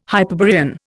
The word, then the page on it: Hyperborean: Indian